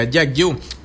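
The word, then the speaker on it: Jack Ü: Se puede complacer ahí con Jack Bu de Skrillex y Diplo, o sea, Jack U, algo así, entonces acabas de pronunciar. Ahí estamos, cuídate, brother. – Hey, saludos, así mero, así se pronuncia, Jack U.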